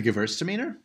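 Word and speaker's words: Gewürztraminer: The Gewurztraminer? – That's the one.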